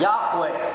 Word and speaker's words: Yahweh: And now the scholar says, we should pronounce the name of the covenant name of the Lord our God as Yahweh.